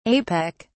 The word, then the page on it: apec: British English